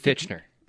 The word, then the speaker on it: Fichtner: Bill Fichtner on the West Her Hotline. I butchered his name just now, didn't I? – It's Fichtner.